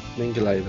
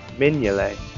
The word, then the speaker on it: Mignolet: How am I supposed to say Mingulay then? – Mignolet. – Mignolet. – Really? – The G is before the N. – Is it? – Yeah. – Mingulay. – Mignolet. – G is silent.